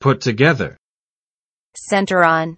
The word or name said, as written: put together